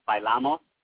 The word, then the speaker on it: bailamos: but you would pronounce it bailamos, like that's how you would say like an accent over the A, that first A, like the bailamos.